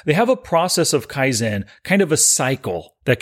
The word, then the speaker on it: Kaizen: All right, word number two is Kaizen, and that is spelled K-A-I-Z-E-N.